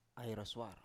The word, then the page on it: ai roswar: Biak